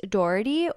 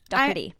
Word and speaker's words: Doherty: And in this episode today, we're going to be talking about Pete Doherty. Or I know that I think if you're from London or from England, it's pronounced Doherty. Pete Doherty.